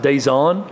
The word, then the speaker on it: Dejon: It's spelled Dejon Terry, but the pronunciation guide says it's pronounced Dazon.